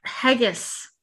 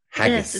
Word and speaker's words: haggis: eating Hoggies. No. – Haggis. – Did I say that wrong? – Haggis. Yes, it's Haggis.